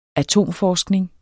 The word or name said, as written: atomforskning